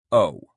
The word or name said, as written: owe